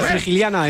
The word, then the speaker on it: Frigiliana: Bueno, confirmamos, Frigiliana en Málaga, sí. – Sí, lo he dicho bien. – No, pero no sé si era por Málaga. – Sí. Pues sí, lo sabía. – Frigiliana, sí.